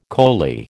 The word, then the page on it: coley: American English